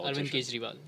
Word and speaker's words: Arvind Kejriwal: Arvind Kejriwal.